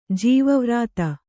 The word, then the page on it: ಜೀವವ್ರಾತ: Kannada